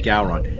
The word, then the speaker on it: Gowron: You have to say it like Gowron.